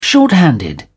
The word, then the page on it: short-handed: British English